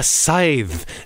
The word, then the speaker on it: scythe: In place of his shotgun, Elmer Fudd now carries a scythe. And I actually had to look up how to pronounce that because I never quite knew how to say it. Scythe.